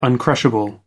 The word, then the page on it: uncrushable: American English